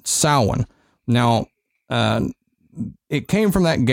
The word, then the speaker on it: Samhain: In Gaelic, the research I've done, it's Samhain. It's like Sowin. – Yeah. – That's how you would pronounce it.